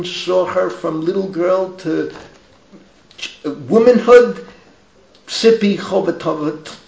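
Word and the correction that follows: Hotovely: how do you pronounce the last name? – Chotaveli. – Sippy Chotaveli.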